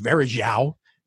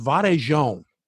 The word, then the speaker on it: Varejão: She says, it's not pronounced Varejao. She said, it's pronounced Varejão, like that. Like São Paulo.